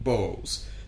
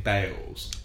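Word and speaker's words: Baals: When this guy was mayor, he pronounced his name Bowles, but his descendants now pronounce the name Bales.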